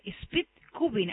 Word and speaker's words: Speed Cubing: ¿sos también el creador de la agrupación misionera de Speed Cubing? ¿Así lo dije bien? – Sí, está bien, sí.